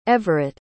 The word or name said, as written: everett